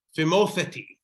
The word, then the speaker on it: Phimotheti: So for the sake of those of you in the internet here who are interested in how to say this, Phimotheti. Is the exact exclamation here. Phimotheti. – Phimotheti. – Phimotheti, TH there.